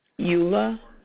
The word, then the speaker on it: Eula: Eula Brown. – Can you spell that for me? – E-U-L-A Brown, B-R-O-W-N.